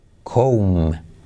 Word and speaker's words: comb: Comb, comb comb. Curioso no confundir con come, come. Peinarse es comb, pero la B no se pronuncia, ¿vale?